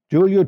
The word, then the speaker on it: Giulio: Giulio Tononi, an Italian American neuroscientist and psychiatrist over the last 20 years.